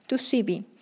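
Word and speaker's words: 2C-B: To cb que se escribe 2CB, que realmente es eh, la pronunciación en inglés, 2CB, También llamada Nexus, cocaína rosa, bromo dragonfly, 2CB fly.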